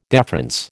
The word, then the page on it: deference: American English